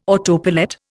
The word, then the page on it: autopilot: Indian